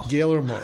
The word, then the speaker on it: Guillermo: We have The Shape of Water from Mr. Guillermo del Toro. Did I say that right? – Guillermo.